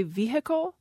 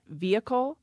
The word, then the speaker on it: vehicle: A good example is the word vehicle. Some speakers say vehicle. I myself have lost the H in that, vehicle. I put the H back in, though, in vehicular, because then the syllable is stressed.